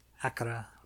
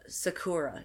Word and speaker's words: Sakura: There Sakura. Are no exceptions. No exceptions. – Sakura. – Like people would say, Sakura. No, it's Sakura. – Sakura.